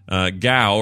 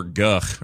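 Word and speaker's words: Gough: Gow or Guff or however you say his name. – Guff. – Guff. Oh, Guff. Is it an F? It's an F. – There's a Damon Guff as lead singer of Badly Drawn Boy, and that's how he pronounces it, and that's how he spells it.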